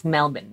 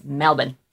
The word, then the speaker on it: Melbourne: It's Melbourne, not Melbourne, like we would have said.